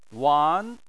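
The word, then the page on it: wan: wan2; tone 2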